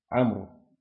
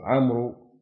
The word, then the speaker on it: عمرو: ولك بعضهم حتى عمرو يقول عمرو غلط، يقول اسمه عمرو عشان يكتب الواو، نقول عمرو عشان يعجل يفرق بينه وبين عمر، نقول هذه عمر وهذه عمرو.